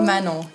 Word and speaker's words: Manon: Manon, you got it.